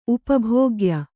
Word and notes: ಉಪಭೋಗ್ಯ: upa bhōgya

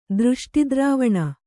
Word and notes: ದೃಷ್ಟಿದ್ರವಣ: dřṣṭi dravaṇa

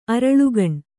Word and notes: ಅರಳುಗಣ್: kannada

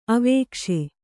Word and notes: ಅವಕೇಶಿ: avakēśi

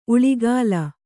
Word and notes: ಉಳಿಗಾಲ: Kannada

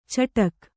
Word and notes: ಚಟಕ್: caṭak

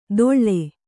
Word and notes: ದೊಳ್ಳೆ: Kannada

